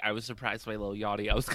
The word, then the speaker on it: Lil Yachty: Somebody who recently surprised me, I honestly never thought I would say this, was Little Yachty. I feel like I'm saying his name wrong. Little Yachty. – Lil Yachty. You're saying it right. Just say Lil, not Little.